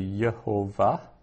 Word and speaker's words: Yehovah: So if I were to pronounce that out, it would be, the way it's written, it would be Yehovah, right? Which is where we get Jehovah from.